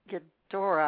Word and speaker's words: Ghidorah: Returner and The Lake House and Godzilla versus King Ghidorah.